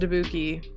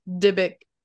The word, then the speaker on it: Dybbuk: Okay, I thought it was pronounced Dybbuk. It's not. It's pronounced Dybbuk.